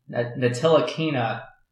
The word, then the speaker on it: Ntilikina: I had, and I don't even know how to say this guy's name fully, but I had Frank Ntilikina? – Ntilikina.